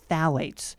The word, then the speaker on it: phthalates: And initially, I thought I was going to mispronounce this word, but I got a little coaching before we hit record, and it is phthalates. – Yeah. – It's not how it's spelled, but phthalates is what we're talking about today.